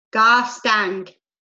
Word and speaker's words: Garstang: Oh, wow. Gaston? – Garstang. – Oh, Garstang. Okay. I don't even know how to pronounce that. You said it the right way.